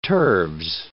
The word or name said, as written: turves